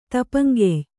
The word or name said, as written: ತಪ್ಪಂಗಾಯಿ